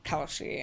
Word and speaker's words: Kelsey: when I go to Starbucks coffee and I say my name is Kelsey and they're like, Kelly? And I'm like, no, Kelsey.